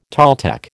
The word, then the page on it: Toltec: American English